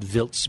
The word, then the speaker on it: Wiltz: It's pronounced Wiltz by the locals.